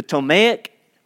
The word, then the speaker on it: Ptolemaic: The Ptolemaic, that's with a silent P, the Ptolemaic and the Seleucid empires.